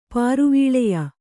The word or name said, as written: ಪಾರುವೀಳೆಯ